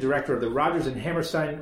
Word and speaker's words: Hammerstein: No, but Bill Hammerstein said to me, like Steinway pianos, Hammerstein. So that's the thing to remember, but it doesn't really matter.